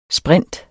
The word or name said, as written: sprinter